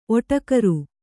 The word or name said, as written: ಓಟಗಾರ